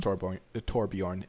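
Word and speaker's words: Torbjörn: Like, yeah, it's Torbjorn.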